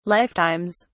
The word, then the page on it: lifetimes: British English